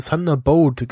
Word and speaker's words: Thunderbolt: äh, der, der, der Schiller hat übrigens einmal ähm, Thunder Thunderbolt gesagt. – Nicht Bolt, sondern Boat.